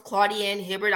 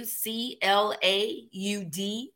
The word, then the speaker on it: Claudienne Hibbert: So a social media, my name is my handles on all platforms, Claudienne Hibbert. I'll spell it for you. Claudienne Hibbert.